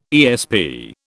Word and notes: ESP: British English

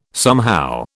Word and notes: somehow: British English

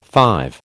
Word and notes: five: American English

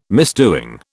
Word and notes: misdoing: British English

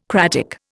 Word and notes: cratic: Indian

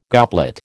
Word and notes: goblet: American English

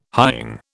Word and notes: hying: British English

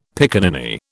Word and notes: pickaninny: British English

